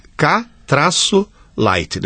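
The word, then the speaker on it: K-Lite: E a palavra-chave para você achar no Google, no Baixa Aqui, é letra K, traço, lite, L-I-T-E. – Isso. – K, traço, light, né? Lite. Sem pronúncia nenhuma. – Isso. Aí vem... Klite.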